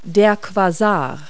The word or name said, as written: quasar